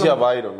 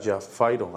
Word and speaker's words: Javairo: Yo es Javairo, ¿no? Y se pronuncia pues con F. – O sea, yo escuché a Vaca, que era Jafairo, algo así.